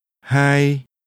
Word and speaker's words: hai: Hai. Hai. Hai. – That's another mid-level tone.